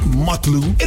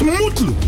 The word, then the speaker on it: Mutlu: It's not Mutlu, it's Mutlu.